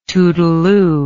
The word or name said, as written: toodleoo